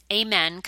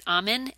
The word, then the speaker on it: Amen: His last name is Amen, kind of like, you know, church, Amen. But I think you pronounce it Amen, A-M-E-N.